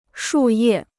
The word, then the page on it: 树叶: shù yè